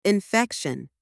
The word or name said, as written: infection